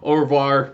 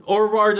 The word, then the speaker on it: au revoir: Yeah, obviously they use this to actually rhyme because it's o o o like you know "au revoir" me rhymes with re- reservoir.